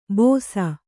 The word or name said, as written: ಬೋಸ